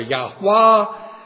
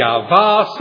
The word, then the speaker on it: Yahweh: But most scholars would say the holy name of God in Hebrew would be pronounced something like Yahweh or Yahwah, Yahweh, Yahvah, something like that.